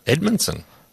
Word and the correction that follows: Amundsen: How do you say it? – Edmundsen. – No, Amundsen, isn't it? A-M-U-N-D-S-E-N.